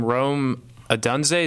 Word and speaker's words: Odunze: Rome Odunze, is that how you say it? – Bingo.